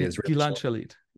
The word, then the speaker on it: Gilad Shalit: Gilad Shalit, I'm sure I'm pronouncing his name correctly. – Gilad Shalit, yeah.